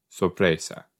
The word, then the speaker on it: sorpresa: I know it's not sopresada. That's a type of meat, sorpresa. So, okay, surprise in Spanish is sorpresa. Yep, I said it right. I think I just said it in an Italian accent.